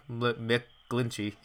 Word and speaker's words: McGlinchey: Mike McGlinchey, yeah, I said it right,